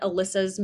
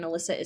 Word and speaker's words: Alyssa: Yeah, so main place to find me is on YouTube at Alyssa's Movie Takes, and Alyssa is spelled A-L-Y-S-S-A.